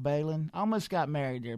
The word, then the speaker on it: Belin: B-E-L-I-N, Balin. – B-E-L-I-N. Okay, Balin. – I got it. – It's Balin.